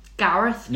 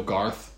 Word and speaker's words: Garth: Gareth? – No, Garth. There's no E.